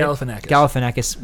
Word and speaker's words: Galifianakis: So that's with Robert Downey Jr. and Zach Galifianakis. Did I say it right? – Galifianakis. – One day I will say his name right.